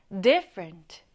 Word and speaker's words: different: 강세는요, 이렇게 앞에 두시면 됩니다. 앞을 강하게 발음해 볼까요? Different.